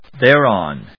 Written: thereon

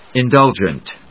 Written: indulgent

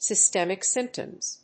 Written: systemic symptoms

